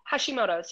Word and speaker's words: Hashimoto's: but it was Hashimoto's, I believe. – Hashimoto's, yep.